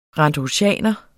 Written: randrusianer

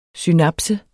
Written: synapse

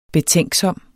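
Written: BETÆNKSOM

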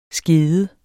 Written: skede

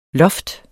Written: lofts

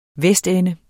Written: vestende

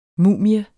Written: mumie

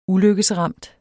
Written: ulykkesramt

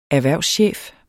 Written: erhvervschef

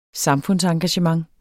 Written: samfundsengagement

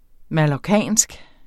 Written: mallorcinsk

